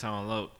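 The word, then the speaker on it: Tone Loc: I don't, is it L-O-C? Is that Locke or Loke? Is that Loke? – We pronounce that Tone Loke. Like the early 90s okay.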